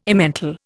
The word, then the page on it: Emmental: Indian